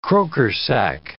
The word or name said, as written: croker sack